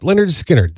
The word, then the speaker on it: Lynyrd Skynyrd: Pronounced Lynyrd Skynyrd.